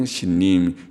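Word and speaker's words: 신님 (סינים): 신님, 옛날 성경이나 NASB 성경 그냥 신님, 히브리 발음대로 신님 사라 땅이라고 그렇게 번역했지만 말이죠.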